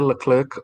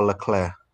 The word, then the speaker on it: Leclerc: Well, they say Leclerc or Leclerc. Because he's a French name, so I think when he speaks English, he pronounces it Leclerc. When like all the English speakers, when they talk to him and talk about him, they call him Leclerc, Charles Leclerc. But he says Charles Leclerc when he talk in English.